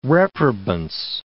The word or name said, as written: reprobance